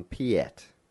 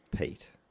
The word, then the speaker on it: Piet: A little while ago, we were calling someone Piet. – Mm. – I found out that it's Pete.